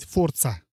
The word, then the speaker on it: Forza: дальше бы Форза, новая Форза. Раз в несколько лет выходит новая Форза. Или, правильно говорить, Форца мы, мы же знаем, про Forza.